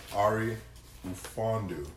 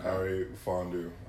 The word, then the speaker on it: Ari Ufondu: My name is Ari Ufondu. – Ufondu, that's how you pronounce that. – Okay. – Ari Ufondu.